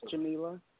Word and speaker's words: Jamila: Swopes, and my first name is Jamila.